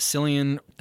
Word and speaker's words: Cillian: I think it's, I pronounce it Cillian. – That's how I pronounce it too.